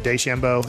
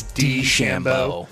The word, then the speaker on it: DeChambeau: And I found out the other day I was saying it wrong when I said DeChambeau. – It's DeChambeau. – Just the D, not day.